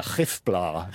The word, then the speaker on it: Gifblaar: Now, I actually asked an Afrikaans speaker how to pronounce that, and it's a Hefbla, apparently. So. – I'll just spell it for our listeners, G-I-F-B-L-A-A-R.